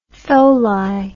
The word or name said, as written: tholi